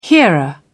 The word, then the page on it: hearer: British English